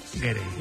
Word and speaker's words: Gere: Estaba pensando yo que si te apellidas Ger, bueno, ¿cómo se dice Ger o Gerre? – Gerre. Me ha hecho gracia porque has dicho Richard Gere y luego el hijo era Gere.